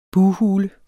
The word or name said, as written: bughule